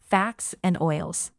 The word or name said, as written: fats and oils